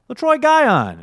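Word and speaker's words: Letroy Guion: But some good news, finally, down this list of the walking wounded, we have LaTroy Guyon. – Oh, you said that right.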